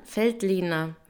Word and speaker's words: Veltliner: Also ein grüner Weltliner. Weltliner? – Feldliner. – Feldliner. Feldliner. Oh Gott. Also auf jeden Fall F mit F am Anfang.